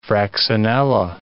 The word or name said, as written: fraxinella